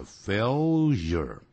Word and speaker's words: failure: 那么现在我要顺便谈一下 failure 这个字，我们老中常常念成呢 failure，但是老美在念的时候叫 failure， failure，它不会有连在一起的声音， failure Failure 啊，一定这样念的。